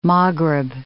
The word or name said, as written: Maghrib